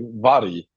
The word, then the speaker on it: Vari: I pronounce my name like Vari 2TM. That's how you say it in Sweden. It's like V-A-R-I almost. Vari.